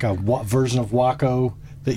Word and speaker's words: Waco: But Weaver Aircraft Company decided to make the pronunciation Waco based on that Indian war cry.